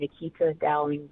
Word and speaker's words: Nequita Dowling: On Instagram is my name, Nequita Dowling, but it's spelled like Chiquita Banana almost.